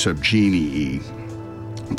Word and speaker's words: subgenii: Politically cynical, subgenii are actually, that should be pronounced subgenii Politically cynical subgenii are nevertheless keen cultural critics whose sophisticated use of a complex comic rhetoric warrants more serious attention.